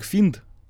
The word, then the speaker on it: Fiend: Фиент — это английский же, э, английское слово, читается как финд, Угу. Пишется просто как фиент.